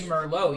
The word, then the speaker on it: Merlot: It's kind of like you don't say a T for like wine. You say Merlot, you don't say Merlot.